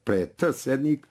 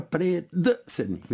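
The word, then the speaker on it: predsednik: Pod šišati, ne pišemo pod t šišati, niti pre t sednik, ali ga izgovaramo. Niko ne izgovara pre d sednik, vidite.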